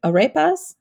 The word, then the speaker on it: arepas: There's no arepas.